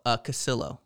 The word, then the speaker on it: Casillo: Oh, Casillo.